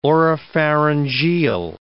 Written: oropharyngeal

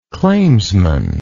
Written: claimsman

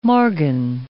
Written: morgen

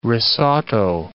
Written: risotto

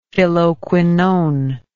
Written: phylloquinone

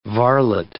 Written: varlet

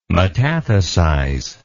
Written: metathesize

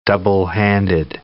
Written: doublehanded

